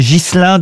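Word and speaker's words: Ghislain: Ogier Gilin de Busbeck, pardon, j'ai écorché son nom. – Il me semblait bien aussi. – Ouais. – Est-ce que vous pouvez appeler Busbeck? – B-U-S-B-E-C-Q. Olivier, pardon, Ogier Gislin de Busbeck. – Ah, Gislin.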